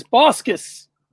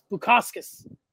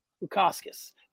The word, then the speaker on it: Bukauskas: Mikhail Oleksiychuk defeated Modestus Bukauskas. No, I missed. Bukauskas. Modestus Bukauskas.